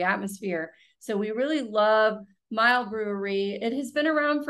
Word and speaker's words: Miel: So it's Mile, M-I-E-L. and it's pronounced Mile, and it's 406 6th Street in New Orleans.